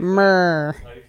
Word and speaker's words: myrrh: Myrrh? – Yeah, that's how you say it. – Myrrh. M-Y-R-R-H.